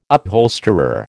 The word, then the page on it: upholsterer: American English